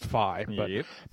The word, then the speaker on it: Faille: last name is spelled F-A-I-L-L-E, but it's pronounced Fey.